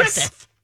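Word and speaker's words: Gruffudd: Griffith. Yes. – But it's spelled IOANGRUFFUDD. So there you go. If you see that name, that's how you pronounce it.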